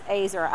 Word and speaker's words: Ezer: So I kind of got to looking up what a helper really looks like, and the Hebrew word for helper is E-Z-E-R, and it's pronounced Azer, I hope.